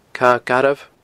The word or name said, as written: Cadha Garbh